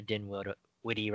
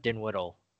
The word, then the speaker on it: Dinwiddie: You got Spencer Dinwiddie, right? Not Dinwiddle. – Yes, Dinwiddie. – Dinwiddie.